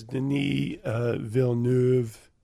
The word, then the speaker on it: Denis Villeneuve: The director is Denis Villeneuve.